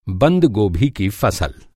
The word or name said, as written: गोभी